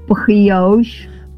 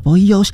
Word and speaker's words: Brioche: 好，它的念法是 Brioche。好，Brioche。